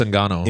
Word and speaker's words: Ngannou: How do you say the other dude's name? Ngannou? – It's Francis Ngannou.